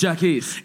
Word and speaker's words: Jacquis: It's Jacquis. – It's Jacquis. – But I grew up here in Chicago with people mispronouncing my name.